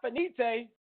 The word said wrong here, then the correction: finito: It's finito. I think it's finito.